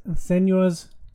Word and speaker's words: Senua's: It depicts Senua's... Did I say it right? – Mm-hmm. You did.